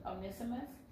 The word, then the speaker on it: Onesimus: Onesimus is coming with Tychius.